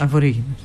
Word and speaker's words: Αβορίγινες: Είναι Αβορίγινες ή Αβορίγινες? – Αβορίγινες. – Αβορίγινες. – Αμπορίτζιναλ είναι τώρα. – Ναι, okay. Όπου θες το τονίζεις.